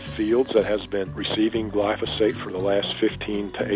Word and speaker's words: glyphosate: The topic of today's show is glyphosate, a word that Greta taught me how to pronounce.